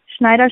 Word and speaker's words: Schneider: Schneider schreibt man S wie Siegfried,